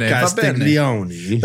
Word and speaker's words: Castiglione: Castiglione. – That was the best pronunciation I've ever heard you deliver, yeah.